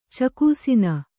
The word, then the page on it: ಚಕೂಸಿನ: cakūsina